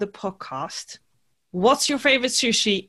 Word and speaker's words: sushi: And now my curiosity, because how do you say sushi in Japanese? – Sushi. – Oh, sushi. So sushi. It's shorter. We say sushi, you know, but Japanese, it's sushi. – Yeah. The emphasis is on the final syllable.